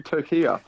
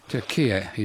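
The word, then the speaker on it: Türkiye: Well, I'll attempt to pronounce it correctly. Turkiye. – Turkiye is correct.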